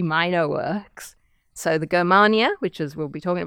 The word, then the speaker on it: Germania: The text that we're talking about is the Germania with a hard G.